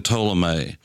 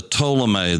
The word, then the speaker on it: Ptolemy: The Ptolemy, that's spelled with a P, P-T-O-L-E-M-Y,